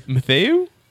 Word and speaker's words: Mathieu: Is that Matthew? – Yeah, Matthew. – Matthew? – Well, it's pronounced Matthew. – It's French. I mean, is it pronounced Matthew? – That's how we pronounce it in America.